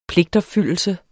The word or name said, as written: pligtopfyldelse